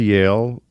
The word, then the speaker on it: Yale: I went to Yale. Yep. Rhymes with jail.